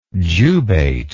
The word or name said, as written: jubate